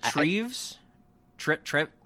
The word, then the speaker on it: Treves: Oh, Dr. What is it, Treves? Trip? – I forget. – I think it's Treves. I think that's how it's pronounced. Yeah, T-R-E-V-E-S, I'm pretty sure.